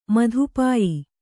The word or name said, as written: ಮಧುಪಾಯಿ